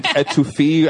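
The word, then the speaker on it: étouffée: I'm yelping like the best, you know, gumbo, shrimp, put it in etouffee. I don't know how you pronounce etouffee. – It. Etouffee. Thank you.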